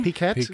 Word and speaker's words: Piquet: It's spelled P I Q U E T. It's French pronunciation. – P I Q U E T. – Is that PK? – Piquette. – Piquette.